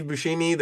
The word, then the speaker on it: Buscemi: Steve Buscemi. There's Joe Pesci. Buscemi.